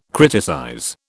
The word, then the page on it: criticize: British English